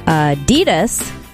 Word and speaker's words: Adidas: Yeah, apparently Americans pronounce Adidas wrong. It's supposed to be Adidas, with a slight emphasis on the first syllable, not Adidas, stressing the second. Apparently that's what we do. So it's supposed to be Adidas, Adidas, not Adidas.